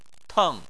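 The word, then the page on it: teng: tone 4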